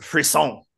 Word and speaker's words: frisson: So the topic today is pronounced Frisson. It is French. I've always said Frisson in my head because I've never actually really researched this.